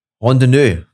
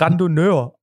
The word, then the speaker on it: Randonneur: Rondonneux. Ne? Rondonneux. – Ich weiß ehrlich gesagt auch nicht, wie man es ausspricht. Ich würde es Randonneur aussprechen. – Okay, okay, geht natürlich. Oh. R-A-N-D-O-N? N-E-U-R, also am Ende Doppel-N.